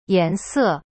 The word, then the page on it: 颜色: yan se